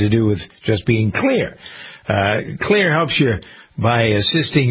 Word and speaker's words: Xlear: There's a product called Clear. You've probably heard me talking about it, spelled X-L-E-A-R.